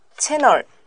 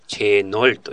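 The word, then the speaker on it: channel: チャンネル。 – チャンネルですね。この場合、チャンっていう A の音、あいの A で発音しちゃうんだよね。で、チェノルというふうに言いますね。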